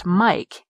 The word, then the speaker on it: mic: I grew up used to the abbreviation for microphone being spelled as well as pronounced mic, M-I-K-E. To me, M-I-C would come out of people's mouths as mic.